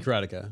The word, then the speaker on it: Karateka: That's how he remade, is it Karateka? Is that how you pronounce it? – Remade Karateka.